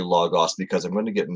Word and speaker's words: logos: So I might interchange between logos and logos, just because I'm not going to push the, I was trained to speak with a very short O omicron, which is the O character in logos. So there isn't a long O there.